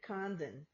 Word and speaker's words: Condon: Condon.